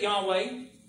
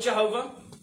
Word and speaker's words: Yahweh: Now, I pronounce it Yahweh. If you want to, you can be wrong and call it Jehovah. I don't mind. That is a misunderstanding of the vowels, I mean of the consonants there in the Hebrew.